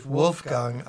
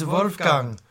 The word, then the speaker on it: Wolfgang: We don't say Wolfgang Amadeus Mozart. It's Wolfgang Amadeus Mozart.